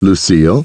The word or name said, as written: Lucille